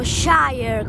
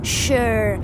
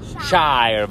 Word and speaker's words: shire: eh, noi dicevamo Shire come eh, Yorkshire e ha detto non si dice Shire ma Shire, Yorkshire, Oxfordshire. Così. Ok, questo è British English. Credo gli americani dicono Shire, vero?